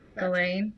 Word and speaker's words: Ghislaine: Ghislaine. How is it? It's either Ghislaine or Galane. – Galane. – Yeah, there's an Maxwell. S in there.